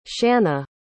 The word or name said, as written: shanna